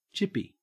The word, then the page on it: chippy: Australian English